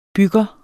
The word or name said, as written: bygger